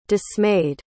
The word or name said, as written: dismayed